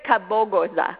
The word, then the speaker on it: Kabogoza: David Kabogoza.